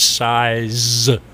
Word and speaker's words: Seiz: My last name is Size, with a Z on the end. – S-I-E-Z. – S-E-I-Z. – Okay, S-E-I-Z.